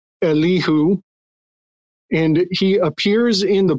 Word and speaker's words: Elihu: It's a fella named, I can hardly pronounce this name, Elihu, E-L-I-H-U, Elihu, right?